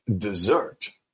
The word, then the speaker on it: desert: When I say dessert, ooh, something's changed, yes. Now I stress the second syllable.